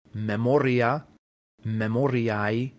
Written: memoria

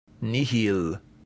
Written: nihil

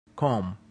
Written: cum

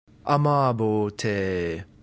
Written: amabo

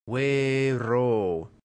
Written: vero

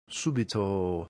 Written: subito

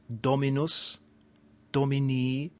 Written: dominus

